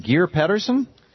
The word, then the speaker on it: Geir Pedersen: It's UN Day, and we're bringing in guests live from the United Nations, and Ambassador, am I pronouncing it, is it Geir Pedersen? – I'm very impressed. This is very good.